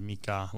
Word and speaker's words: Mika: people were saying Mika, but the pronunciation is Mika, like two syllables. It's the Hmong word for American.